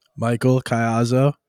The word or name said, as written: Caiazzo